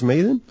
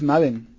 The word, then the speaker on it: Madden: De que sí van a haber franquicias, básicamente también va a haber Madden, bueno, no sé si pronuncia Madden o Maiden, ya lo he escuchado de las dos maneras. – ¿Maiden? – La franquicia de americano es Maiden. – Es Madden.